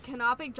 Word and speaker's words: canopic: And then they would use jars called canopic jars. I think I'm saying that right. – Yes. – Canopic jars that they would put the organs in to preserve.